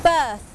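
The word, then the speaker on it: berth: B-R-T-H birth อ่านออกเสียงเหมือนกันกับคำว่า birth B-I-R-T-H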